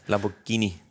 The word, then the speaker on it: Lamborghini: Hvor han har skaffet mig at køre Lamborghini og sådan noget. – Der for guds skyld. Når du Lamborghini. – Til. Nej, Lamborghini.